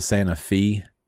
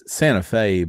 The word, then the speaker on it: Santa Fe: But it's Santa Fe, but in the Tennessee one, it is Santa Fe. I have a major problem with it, but I do understand that's how you say it.